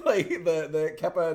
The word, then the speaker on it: kippah: How's it pronounced? I always get that one wrong. – Kippah. – That's because there's another word, K-E-P-I, which I think is pronounced kepi, which is the name of the hats from the Civil War.